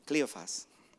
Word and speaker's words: Cleophas: And I was wondering how you pronounce that in, you say it in English. What's his name? Cleophas.